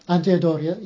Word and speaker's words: Andrea Doria: But what's also interesting, Admiral Andrea de la Doria, if I get his name right. – Andrea Doria? – Andrea Doria, yes, thank you.